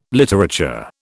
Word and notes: literature: British English

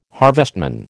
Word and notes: harvestman: American English

